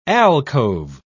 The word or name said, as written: alcove